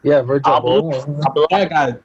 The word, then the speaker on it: Virgil Abloh: Virgil Abloh.